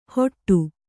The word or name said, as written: ಹೊಟ್ಟು